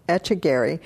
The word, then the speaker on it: Echegaray: Echegaray is the correct pronunciation of his last name.